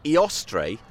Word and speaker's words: Eostre: Eostre, I think it's pronounced, E-O-S-T-R-E, was a goddess of spring or renewal, and that's why the feast is attached to the vernal equinox.